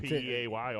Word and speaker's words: Peay: So this dude, Jontarius Bryant, is a receiver for Austin Peay. – Is that how you say it? P E A Y, Austin Peay.